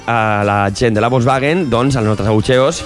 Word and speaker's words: Volkswagen: No, pronuncia bien Volkswagen. – Volkswagen.